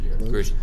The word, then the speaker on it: Grusch: What's interesting, though, is this latest whistleblower, David Grush, I think his name is pronounced Grush.